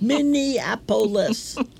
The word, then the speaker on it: Minneapolis: And did you ever really realize that the word Minneapolis is five syllables until I heard Bruce Springsteen sing about it? Minneapolis.